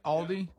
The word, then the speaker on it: Aldi: Aldi? – Yeah, mm-hmm.